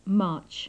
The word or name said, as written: march